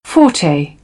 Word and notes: forte: British English; ɔː

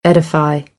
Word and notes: edify: American English; ə - ɪ

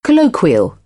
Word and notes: colloquial: British English; əʊ

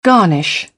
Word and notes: garnish: British English; ɑː

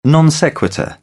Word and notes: non sequitur: British English; t

